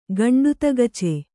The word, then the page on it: ಗಂಡುತಗಚೆ: Kannada